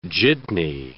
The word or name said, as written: jitney